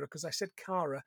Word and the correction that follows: Kira: because I was so upset with Mono. I mispronounced the name.